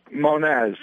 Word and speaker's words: Monez: Monez. It's Portuguese.